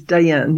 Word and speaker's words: Dayan: Most people don't. It's Dayan, yeah.